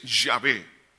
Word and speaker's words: Yahvé: Por cierto, la pronunciación es tan difícil que muchos teólogos no se han puesto de acuerdo en cuanto a la pronunciación exacta, pero se acepta que es Yahvé.